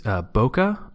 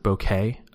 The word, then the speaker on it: Bokeh: Yeah, so I picked bokeh, or you can also pronounce it bokeh. In the official FAQ for the project, it says you can pronounce it either way.